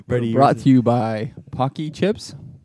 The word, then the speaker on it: Pocky: Pocky. Yeah, that's how you pronounce it.